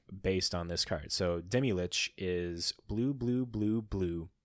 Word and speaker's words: Demilich: It is Demilich. Is that how you pronounce that guy's name? Demilich? – Yeah, the Demilich.